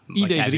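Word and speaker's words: ID.3: de Volkswagen heeft de productieversie van de, zeg ik dat goed, gewoon de ID3. Ja, ID3.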